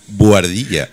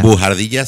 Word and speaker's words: buhardilla: Bujardilla suena como una palabrota. – Ah, sí. Buhardilla. Ah, vale. – Buhardilla. Vale, la H es muda en español. – Ah, que no se pronuncia. – Claro, y tú dices bujardilla y suena como bujarrón y entonces suena raro.